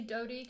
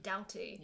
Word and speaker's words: Doughty: I say Doughty because that's how she pronounces it, but I would mm-hmm. say Doughty, yeah. – because I'm really English.